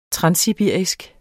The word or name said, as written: transsibirisk